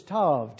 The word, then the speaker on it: Tav: And so you can spell it if you want to, A-L-E-F or A-L-E-P-H, Alpha. The last letter of the Hebrew language is Tav, T-A-V.